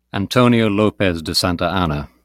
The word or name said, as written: Antonio López de Santa Anna